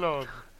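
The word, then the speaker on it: Jolyon: Was it Jolon? I think that's how you pronounce it. – Jolon. like colon. – Yeah. – Jolon. – Jolon. – Okay, let's go with Jolon.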